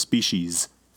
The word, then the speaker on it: species: At first, plans to use only variations that already occur in the species. His hornless Holsteins are all cow. – Is it species or species? – Species. – Rhymes with feces.